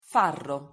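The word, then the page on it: farro: Italian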